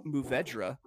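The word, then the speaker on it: Mourvèdre: The number two for you non-wine snobs is pronounced Muvedra.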